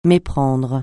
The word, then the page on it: méprendre: francais